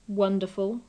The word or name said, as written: wonderful